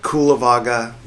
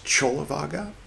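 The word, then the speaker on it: Cullavagga: This is Buddhist Books Podcast, episode 331, Tipitaka, part 212, in which we'll continue reading, spelled Kulavaga, but in the original Pali, apparently, it's pronounced Chulavaga, C-U-L-L-A, Chola.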